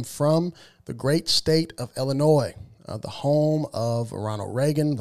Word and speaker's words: Illinois: And it's pronounced Illinois. The S is silent.